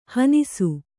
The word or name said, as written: ಹಣ್ಣಿಸು